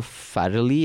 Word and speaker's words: Farrelly: But before that, it's been directed by Peter Farrelly.